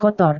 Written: kotor

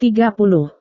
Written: tiga puluh

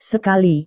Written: sekali

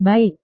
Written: baik

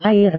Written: air

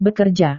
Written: bekerja

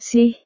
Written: sih

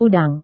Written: udang

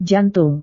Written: jantung

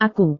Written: aku

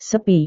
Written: sepi

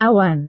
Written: awan

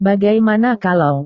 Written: bagaimana kalau

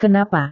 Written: kenapa

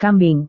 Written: kambing

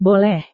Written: boleh

